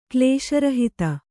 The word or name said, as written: ಕ್ಲೇಶರಹಿತ